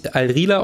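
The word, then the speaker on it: Al Rihla: Und der Ball heißt richtig Die Reise und auf Arabisch Al-Rila ausgesprochen.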